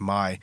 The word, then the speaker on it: Mye: So the third song, I guess it's pronounced My. – That's what I thought. – M-Y-E.